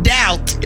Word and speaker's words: doubt: The first text, the national keyword, doubt. D-O-U, unnecessary B-T.